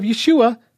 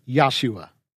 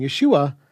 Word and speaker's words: Yeshua: So the name of Yeshua does contain the Father's name after all, and we should restore the pronunciation to Yahshua. Well, not so fast. While there's certainly some validity to the idea of Yud-Heh-Vav-Heh still being preserved in the name Yeshua, that still doesn't affect the pronunciation of what we clearly see in the Hebrew.